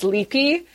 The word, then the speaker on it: Lupe: And I, I also the female narrator, um, the character's name, it's, it looks like it's Lupe, but it's actually pronounced Leapy the little crazy maniacal torturer with uh, with narcissists.